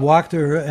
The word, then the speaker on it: Wachter: Also, I have a quick question, which is, could you please comment on the opinion piece in the Washington Post by Dr. Wachter, I think his name is pronounced, who is the chair of medicine at UCSF, recommending the single dose with a delayed second dose, as they are doing in the UK. – Yeah, it's Bob Wachter and Professor yes.